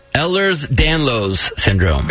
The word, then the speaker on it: Ehlers-Danlos syndrome: Ehlers-Danlos syndrome. – Very good. – Yes. – Did I pronounce it correctly? – You did.